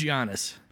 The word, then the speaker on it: Giannis: It's Giannis.